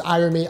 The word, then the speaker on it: Irami: That's my name is Irami, IRAMI.